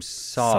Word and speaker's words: Seo: And you pronounced her name Saw. – Saw. – Saw, but Saw. – It's sort of transliterated, spelled S-E-O.